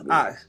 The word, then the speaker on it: AVX: I like the spelling of your name, AVX, but it's pronounced Ox.